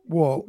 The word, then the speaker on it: Wuhl: Actually, it's pronounced – my family always pronounced it Wuhl, almost like the side of a building. – It is Wuhl, yeah.